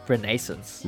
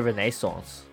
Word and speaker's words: Renaissance: Renaissance. – Renaissance， Some people like to say Renaissance.